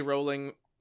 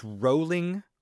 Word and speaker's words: Rowling: It was I think it's rolling like row a boat, not rowling like we had a row.